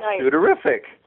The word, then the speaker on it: sudorific: And that's sudorific. – Oh, sorry. – No, I'm and being facetious. I'm using that colloquially. It's sudorific.